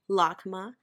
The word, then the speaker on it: LACMA: the locals say it, LACMA, the LA Contemporary Museum of Art, I think.